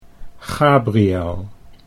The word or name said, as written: Gabriel